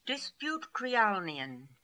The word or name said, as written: Disputekrialnian